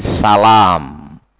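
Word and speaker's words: Salām: Nah, zahaba ba'duhum bitasydid, sebagian mereka berpendapat dengan mentasydid salam Muhammad ibnu Salam, ya, dengan mentasydid lamnya. Barakallahu fikum. Wa zahaba ba'dhum bitakhfif. Dan yang lain berpendapat dengan ya membacanya tanpa tasydid. Ya, Muhammad bin Salam. Barakallahu fikum. Naam. Wa huwa rajih. Wa huwa rajih. Dan itulah pendapat yang kuat. Muhammad ibnu Salam tanpa tasydid.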